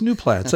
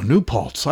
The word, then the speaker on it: New Paltz: He says, "New Platts." I said, "Look at that." Oh, New Paltz. I've been saying it wrong for 5 years.